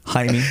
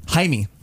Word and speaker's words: Jaime: Jaime. – No. – Yeah. – Jaime. – Because they don't say the J.